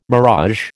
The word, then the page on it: mirage: American English